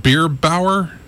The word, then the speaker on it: Bierbauer: Until 1891, when they signed Lou Bierbauer. B-I-E-R-B-A-U-E-R.